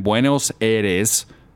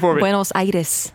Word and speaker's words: Buenos Aires: If you guys haven't heard it, this one is called That One Night Live in Buenos Aires. Did I say that right? – No. – Say it for me. – Buenos Aires. Buenos Aires.